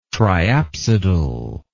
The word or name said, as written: triapsidal